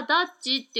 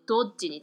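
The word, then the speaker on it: Dodge: だから日本語ではダッジって車の名前になってるんでしょうけど、えー、発音的にはドッジに近いです。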